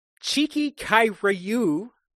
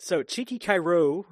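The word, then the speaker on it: Tchéky Karyo: And this guy is played by, I had to look up the pronunciation, Cheeky Kai Ryu. It's spelled like T-C-H-E-K-Y and then K-A-R-Y-O. Cheeky Kai Ryu, or...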